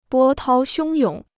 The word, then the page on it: 波涛汹涌: bō tāo xiōng yǒng